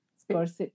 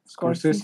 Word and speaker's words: Scorsese: Scorsese ¿Cómo se pronuncia? Scorsese.